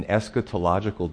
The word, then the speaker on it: eschatological: Also, an eschatological discussion, which is six syllables.